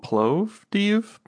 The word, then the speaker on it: Plovdiv: But yes, let's go to Plovdiv, Bulgaria.